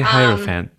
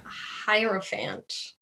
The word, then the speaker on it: Hierophant: Is it Hiero or Hero? – Hierophant. I've heard it pronounced so many different ways. – I need to look it up. It bothers me that I don't know for sure. – I say Hierophant.